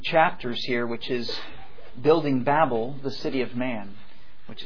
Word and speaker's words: Babel: Now, let me back up and say I recognize the correct pronunciation is Babel, but I've said Babel so long I will continue to say Babel.